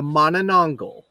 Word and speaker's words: Manananggal: This is how I believe you pronounce it. Tonight is M for the Manananggal. – Manananggal. – And for those playing at home, that is spelled M-A-N-A-N-A-N. G-G-A-L, Manananggal.